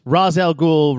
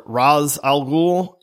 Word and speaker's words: Ra's al Ghul: Yeah, it really, because it's a comic, it's Ra's al Ghul, Ra's al Ghul, Ra's al Ghul. I've always pronounced it Ra's al Ghul.